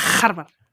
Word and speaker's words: Harvard: Encántame como dices Harvard. – ¿Cómo se dice? – Harvard. – ¿Y cómo digo? Encántame. – Con una J muy agresiva. Como Harvard. – Harvard.